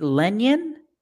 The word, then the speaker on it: Lenyn: Lenyon? What do you get? L-E-N-Y-N? – Yeah.